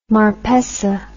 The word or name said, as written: Marpessa